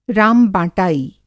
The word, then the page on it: राम-बाँटाई: Hindi